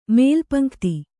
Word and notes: ಮೇಲುಪಂಕ್ತಿ: Kannada

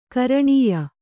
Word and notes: ಘ್ರಾಣೀಯ: ghrāṇīya

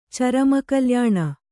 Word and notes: ಚರಮಕಲ್ಯಾಣ: Kannada

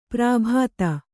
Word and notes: ಪ್ರಾಭಾತ: prābhāta

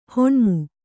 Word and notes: ಹುಣ್ಣಮಿ: Kannada

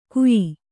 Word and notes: ಗುಯಿ: Kannada

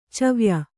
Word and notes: ಚವ್ಯ: cavya

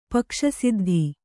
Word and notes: ಪಕ್ಷಸಿದ್ಧಿ: Kannada

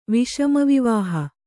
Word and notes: ವಿಷಮವಿವಾಹ: viṣama vivāha

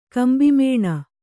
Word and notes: ಗುಂಭಮೌನ: gumbha mauna

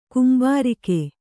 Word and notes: ಕುಂಬಾರಿಕೆ: kumbārike

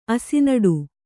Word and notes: ಅಸಿನಡು: Kannada

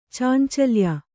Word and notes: ಚಾಂಚಲ್ಯ: Kannada; cāncalya

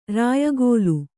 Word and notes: ರಾಯಗೋಲು: rāyagōlu

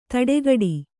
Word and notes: ತಡೆಗಡಿ: taḍegaḍi

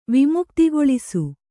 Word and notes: ವಿಮುಕ್ತಿಗೊಳಿಸು: vimuktigoḷisu

